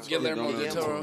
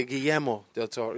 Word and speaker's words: Guillermo del Toro: Guillermo del Toro. – You don't call him del Toro. – Like in Latin, you say Guillermo del Toro.